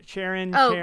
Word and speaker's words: Charon: No, Karen? I mean, Charon. – Charon.